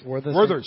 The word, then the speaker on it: Werther's: Was it Werther's? How do you say it? – Werther's. – Werther's. – Werther's, yeah, the toffee candy.